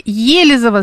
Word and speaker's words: Елизово: Ударение в слове Елизово ставится на первый слог.